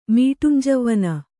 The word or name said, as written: ಮೀಟುಂಜವ್ವನೆ